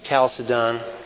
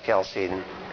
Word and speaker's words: Chalcedon: Sometimes it's Chalcedon, sometimes it's pronounced Chalcedon. I've heard it both ways by scholars who study it, so beats me which one.